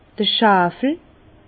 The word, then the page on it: d'r Schwawel: Bas-Rhin; Herrlisheim